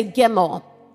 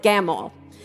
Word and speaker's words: gimel: Everybody say gimel, or based on how you might pronounce it, gamel.